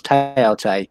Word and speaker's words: Thoth: Thoth that some people pronounce Teote is an abbreviation of Thought.